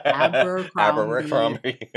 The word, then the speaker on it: Abercrombie: Like I said, do you want to go on an Abercrombie and Kent? – She keeps adding an extra syllable in there. – Abercrombie.